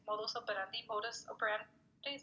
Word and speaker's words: modus operandi: It's just like the same modus, I don't know how to say it, it's modus operandi, is that how you call it? – Yeah.